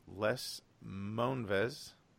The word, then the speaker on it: Moonves: First thing is, and I'm probably going to butcher his name, CBS Chief Les Moonves. Am I pronouncing that right? – Yes.